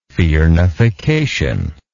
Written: firnification

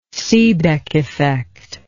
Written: seebeck effect

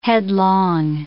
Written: headlong

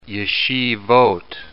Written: yeshivot

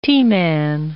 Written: T-man

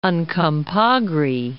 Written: Uncompahgre Peak

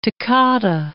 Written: toccata

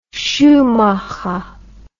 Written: schumacher

